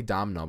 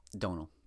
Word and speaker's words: Domhnall: I say Domhnall, but you say it's Donal, right? – Donal. Donal Gleeson.